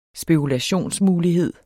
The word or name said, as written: spekulationsmulighed